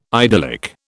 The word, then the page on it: idyllic: British English